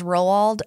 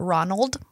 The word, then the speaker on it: Roald: I wasn't, I was 21 when I learned that his name was Roald and not Ronald.